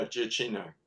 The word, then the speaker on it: Giacchino: He did the music for the movie, and I think I've really butchered that last name. – No, you're right. Yeah, Giacchino.